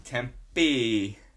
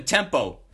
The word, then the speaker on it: Tempe: Tempe, Arizona. – Tempe. I can never live there. I'm like, where do you live? I live in Tempo.